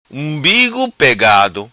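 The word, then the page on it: Umbigo Pegado: Macanese Patuá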